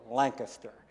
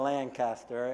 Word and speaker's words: Lancaster: And so I had to learn to say Lancaster. What helped me was in the Lancaster Intelligencer, they actually spelt it out, how you should pronounce it, L-A-N-K-I-S-T-E-R, so Lancaster, instead of Lancaster.